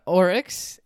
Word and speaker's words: aurochs: Well, this week we're doing the Oryx, and... I'm probably mispronouncing that name as well, but it's spelt A-U-R-O-C-H-S.